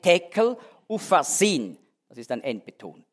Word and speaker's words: tekel: Mene, mene, tekel, die erste Silbe betont.